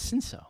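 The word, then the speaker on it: Cinsault: I thought it was Cinso. – So I take my knowledge of Italian pronunciation, and the C-I is chi, so that's what I did. It's not accurate, it's not correct, so, well, anyway.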